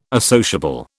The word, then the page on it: associable: British English